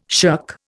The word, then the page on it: shirk: Indian